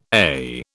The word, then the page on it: eh: British English